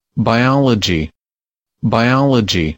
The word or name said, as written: biology